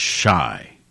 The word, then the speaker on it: Shai: I think his nickname was Shy, but it's spelled S-H-A-I.